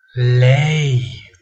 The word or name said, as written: lei